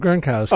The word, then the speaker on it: Gronkowski: Gronkowski. – Gronkowski.